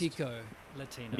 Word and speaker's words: Mi Chico Latino: Firstly, I need to correct you. It's Mi Chico pronounced. – Mi Chico Latino, Mi Chico.